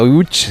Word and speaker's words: Łódź: Aunque se pronuncia Łódź, se escribe Lodz, por si alguien lo busca Lodz, en Google. Casi no tiene nada que ver la pronunciación.